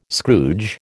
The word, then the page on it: Scrooge: American English